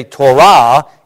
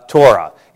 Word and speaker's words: Torah: But in Hebrew, you tend to accent words on the last syllable. So in Hebrew, we say Torah. In English, we make it Torah.